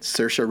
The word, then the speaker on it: Saoirse: Anyways, Correct me if I'm wrong, especially Shay, if you're still listening, on pronunciation here, but Saoirse Ronan? – Yep.